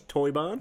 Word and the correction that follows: Teuber: And Klaus Teuber.